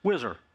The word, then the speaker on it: WHSR: It's now referred to as the WHSR, pronounced Whizzer, for White House Situation Room.